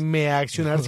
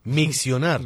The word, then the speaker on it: miccionar: ¿Vos te imaginas que uno se baja a meaccionar en plena carrera? – Cochise, qué pena, pero no se dice meaccionar, sino miccionar.